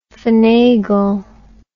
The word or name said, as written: phenagle